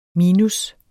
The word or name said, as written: minus